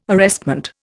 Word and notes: arrestment: Indian

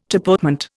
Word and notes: tapotement: Indian

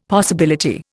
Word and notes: possibility: Indian

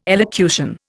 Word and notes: elocution: Indian

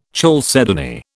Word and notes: chalcedony: British English

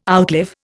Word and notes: outlive: Indian

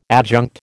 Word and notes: adjunct: American English

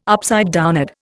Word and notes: upside down: Indian

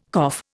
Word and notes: cough: Indian

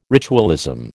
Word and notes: ritualism: American English

